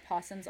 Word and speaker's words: opossum: I just wanted to cover one more cool thing about possums. Also, it actually is about to do with evolution. So possums are, even though it's spelled opossum, you still pronounce it possum, by the way.